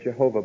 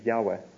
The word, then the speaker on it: Yahweh: Now, we don't have a real date for this, but a French fellow, yes, J-E-D-P, the J stands for Yahweh, that's God's name, Yahweh. A lot of people say Jehovah, but his name is Yahweh, is the way it's to be pronounced. Y-H-W-H.